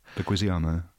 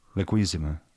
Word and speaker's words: Leguizamo: Yeah, and poor little tiny John Leguizamo, whatever his name is. What's his name? I'd say his name. – Leguizamo.